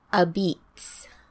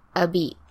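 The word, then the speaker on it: apizza: And they pronounce it... a beats. So they don't say pizza, they say a beat. – So nobody in New Haven orders pizza, they all say a beak? Is that what you're saying? – A beat, like a red beet. – Oh, a beet. – Like multiple beets, except a beet.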